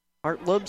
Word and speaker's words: Hartlaub: Hartlib, spelled H-A-R-T-L-A-U-B. The A is silent.